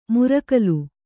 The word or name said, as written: ಮುರಕಲು